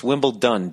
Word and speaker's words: Wimbledon: For years I've been saying Wimbledon. Yet another word that I've been mispronouncing. T-O-N, Wimbledon. But it's Wimbledon, D-O-N.